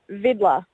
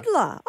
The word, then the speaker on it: Vidler: Vidler. – Vidler? – Vidler. – V-I-D-L-E-R.